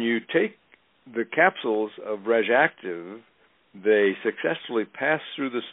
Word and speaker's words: RegActiv: The name of this brand name of product is called RegActive. That's spelled RegActiv.